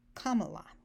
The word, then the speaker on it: カマラ: カマラ、日本語で言うとカメラってあるじゃないですか。カメラの目を間にするの。ポイントは「か」がアクセントです。カメラじゃなくてカマラ、カマラ、カマラ、次期大統領カマラ、カマ、ごめん、カマラ・ハリスさん、よろしくお願いします。